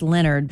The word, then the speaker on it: Lenard: I'm assuming it's pronounced Leonard, but it's spelled Leonard, L-E-N-A-R-D.